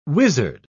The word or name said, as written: wizard